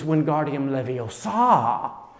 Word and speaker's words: Wingardium Leviosa: It's Wingardium Leviosa, she tells them. Because if they don't pronounce the Latin right and put the right emphasis on the right syllable,